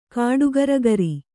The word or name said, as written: ಕಾಡಗರ್ಗರಿ